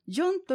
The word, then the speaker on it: 煎: ジョンという言葉は耳慣れないかと思いますが、煎茶の煎と書いてジョンと言います。